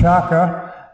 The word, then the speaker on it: Kiaka: The sign says Kiaka. It's actually pronounced Chaka. The K in the Luganda language is pronounced CH, and this is the road leading off the main road back into the Chaka 2 refugee camp.